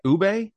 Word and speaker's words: ube: Ube, UBE, I think is how it's spelled.